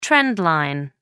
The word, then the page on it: trend line: American English